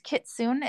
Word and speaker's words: Kitsune: I think it's pronounced Kitsune. It's Kitsune.